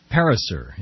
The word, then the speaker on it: Pariser: Eli Pariser, is that correct pronunciation, mm-hmm.